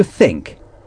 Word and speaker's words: think: To think. Think s'écrit T-H-I-N-K. To think. Il faut prononcer le T-H.